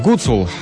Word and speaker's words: Гуцул: Джей-Би-Джей, Кирюха и Гуцул. Да что ж я ударение не могу? Гуцул, вот правильное ударение.